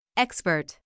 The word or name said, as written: expert